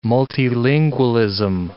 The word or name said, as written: multilingualism